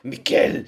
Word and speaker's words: Michel: I say Michel, I googled the actual pronunciation in German and it's Michel.